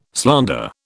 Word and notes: slander: British English

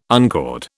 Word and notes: uncord: British English

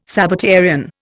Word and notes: Sabbatarian: Indian

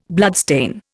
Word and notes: bloodstain: Indian

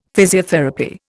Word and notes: physiotherapy: Indian

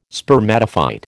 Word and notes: spermatophyte: American English

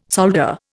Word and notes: solder: Indian

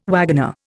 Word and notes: wagoner: Indian

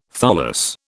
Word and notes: tholos: British English

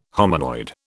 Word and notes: hominoid: British English

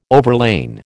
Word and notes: overlain: American English

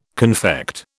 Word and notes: confect: British English

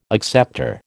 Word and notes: acceptor: American English

